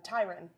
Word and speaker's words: Tyrann: And I think that a huge signal for that was when they signed Tyrann Mathieu, Byron Tyron. We've been told that Tyrann Mathieu, it rhymes with Byron. If you ever get confused, just say Byron. You can go Tyron off of that.